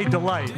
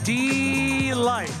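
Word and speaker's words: Deee-Lite: Don't say delight. You have to say delight.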